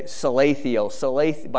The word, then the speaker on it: Shealtiel: Son of Shealtiel. Shealtiel, or it's spelled a different way, Salathiel. By the way, Salathiel and Shealtiel is the same name, so when you see it, it's a little confusing sometimes.